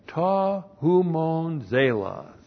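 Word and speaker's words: Ta Humon Zelos (τὸ ὑμῶν ζῆλος): 1, we have ta humon zelos. Ta humon zelos. T-O-H-U M long O N Z long E L O S Ta Humon Zelos.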